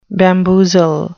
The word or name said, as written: bamboozle